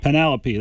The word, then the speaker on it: Penelope: Penelope, there you go.